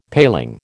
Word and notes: paling: American English

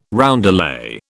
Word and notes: roundelay: British English

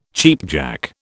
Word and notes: cheapjack: British English

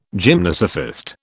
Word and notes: gymnosophist: British English

